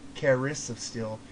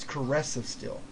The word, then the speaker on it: Caress of Steel: I pronounce it Caris of Steel.